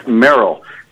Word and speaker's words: Merrill: Merrill. That's spelled M-E-R-R-I-L-L.